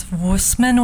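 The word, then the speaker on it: Wößmann: I think it's Wussmann with like a V.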